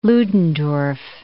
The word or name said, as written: Ludendorff